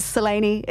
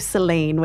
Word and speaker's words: Selene: And it's actually a very kind of twinkle in the eye, tongue-in-cheek first line of the show where we clarify for everyone that Selene is how we're saying it, as opposed to Selene, which lots of people tend to go to. but yes, Selene is how we pronounce it.